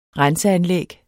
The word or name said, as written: renseanlæg